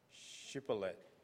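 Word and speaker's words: Shibboleth (שבולת): So they asked them to pronounce the name of the river, and then say they were asked them to say, Shibboleth. – 好啊，Shibboleth 哈。哎，但是啊，哦，这个以法莲人讲话有 accent 哦，有口音哦，他们没办法发这个 sh 的音哦，他们都发成 s 的音啊，所以变成希伯列去了。 – Because Ephraim, they have the accent, so they were not able to pronounce Shibboleth.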